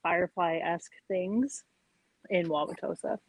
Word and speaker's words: Wauwatosa: Yeah, it's Wauwatosa. – Wauwatosa. – And we keep saying Tosa. – Okay. I was like, I'm looking at my notes here and I'm like, what is happening? Do you not pronounce anything before the T? – It's all silent.